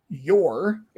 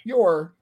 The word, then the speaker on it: Yor: And then another convoluted set of circumstances make it so that both Lloyd and Yor, it is Yor, right? Or is it? – It is. It's, yeah. I mean, it's written Yoru, but the author also insists that it is spelled Yor.